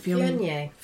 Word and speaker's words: Viognier: Viognier? How do you say it? – Viognier.